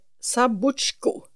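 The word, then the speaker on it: Zabuzhko: Hon heter Oksana Sabuchka. Nej, Sabuchko. – Mm-hmm. – Om jag uttalar det rätt.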